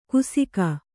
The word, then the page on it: ಕುಸಿಕ: kusika